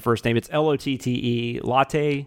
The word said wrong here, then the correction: Lotte Lenya: Yeah, I actually heard this name pronounced several times, but I don't remember if it's exact, but it's Lotte Lenya, I think. – Okay, Lotte Lenya. That makes sense.